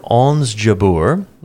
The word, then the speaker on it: Ons Jabeur: Question number one, born in 1994, Ons Jabeur is a professional athlete playing what sport? So Ons Jabeur, which is spelled O-N-S, first name, last name J-A-B-E-U-R, is a professional athlete playing what sport?